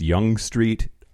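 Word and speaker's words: Yonge Street: The other thing is, apparently it's pronounced Young Street.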